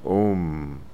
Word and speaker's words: Om: And in answer to the question from our cyberspace friend in Asia, how do you pronounce Om? It's A-U-M. And true, it's also pronounced Om. But the esoteric mystical pronunciation is A-U-M.